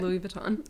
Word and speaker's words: Louis Vuitton: He was wearing a Louis Vuitton, is that how you say it? – Louis Vuitton.